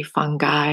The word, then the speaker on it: fungi: And today, Moon Day, June 1st, the waxing gibbous moon, actually gibbous moon, I somehow like to use the soft G instead of the hard G. So anything that that comes with the G, I would say I would use a soft G instead of hard G, but like fungi is actually fungi and gibbous is actually gibbous.